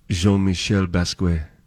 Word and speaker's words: Jean-Michel Basquiat: Jean-Michel Basquiat.